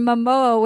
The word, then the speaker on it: Momoa: My boyfriend described Leo as Sean William Scott in a Jason Momoa wig, and now I can't unsee it.